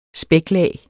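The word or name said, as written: spæklag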